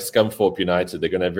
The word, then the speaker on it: Scunthorpe: No, but it's Scunthorpe. You don't pronounce the TH, it's with an F.